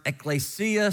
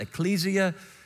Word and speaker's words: ekklesia: It's a Greek word, ekklesia. Some pronounce it ecclesia.